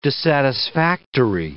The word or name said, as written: dissatisfactory